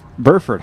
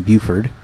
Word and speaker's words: Burford: The Dwarf Burford Holly, I think I've been saying that wrong this whole time. I've been saying Buford.